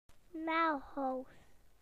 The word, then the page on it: nails: British English